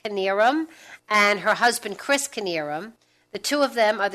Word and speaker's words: Knierim: It's spelled K-N-I-E-R-I-M. So when you look at it, you're like, how do you pronounce that? Is it Nearum? What is that? But it's Kinnearum.